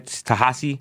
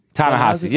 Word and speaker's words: Ta-Nehisi: Tahasi? – Yeah, Coates? – I don't know how to say his name. Tallahassee? – Ta-Nehisi, yeah.